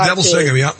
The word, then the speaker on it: Neville Singham: I mean, either George Soros, there's also this very wealthy man, Neville Singham, I think I'm pronouncing his name correctly. – It is. It's Neville Singham,